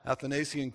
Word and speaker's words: Athanasian: It was I can't remember how they said it now, but it didn't sound like Athens. So anyhow, Athanasian is what we'll go with today. Since no one else knows, I'll just tell you that's how you pronounce it. Athanasian Creed.